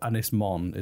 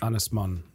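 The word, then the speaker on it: Ynys Môn: How do you pronounce it again? – Anglesey or Ynysmon is the Welsh. – Ynysmon.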